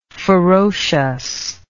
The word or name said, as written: ferociousness